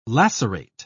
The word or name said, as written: lacerate